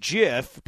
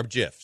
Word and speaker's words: GIF: Inventor of the GIF says it's pronounced GIF, but the G means graphic, so it should be GIF.